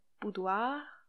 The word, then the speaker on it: boudoir: We will try to give you the perfect neutral accent, which is not the Parisian one for sure. So boudoir, boudoir, boudoir.